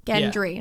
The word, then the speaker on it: Gendry: Gendry? Gendry, yeah. – I'm saying it right? – Yeah.